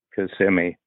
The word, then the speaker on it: Kissimmee: But I'm sorry, Kissimmee. Kissimmee. I mispronounced it.